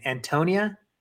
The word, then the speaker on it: Antonina: Every Shevchenko fight for Antonia is dude, God, I know I butchered it again. Antonina.